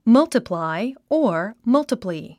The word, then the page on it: multiply: mʌ́ltəplài; マルチプライ